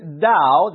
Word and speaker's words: Tao: with this Tao. They call it a Tao, pronounced with a D, but spelt with a T, T-A-O.